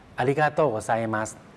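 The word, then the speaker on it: อ าริ กาโต โก ไ ซมั ส (ありがとうございます): อาริกาโตโกไซมัสตรงซาอิก็ออกเสียงเร็วนะครับเป็น 2ช่วงเสียงไซไซครับแล้วก็มัสนะครับมัสก็ออกเสียงเร็วเป็น 2ช่วงเสียงเหมือนกัน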